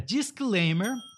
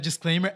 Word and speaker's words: disclaimer: Disclaimer. – Aviso legal. – E a minha pronúncia não é disclaimer, é disclaimer, como se tivesse alguém tocando lamer.